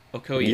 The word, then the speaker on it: Okoye: Remind me, how do I say her name? Is it Okonya? – Okoye. – Yeah. – Okoye, thank you.